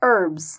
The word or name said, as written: herbs